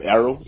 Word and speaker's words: Errol: Errol.